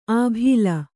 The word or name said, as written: ಆಭೀಲ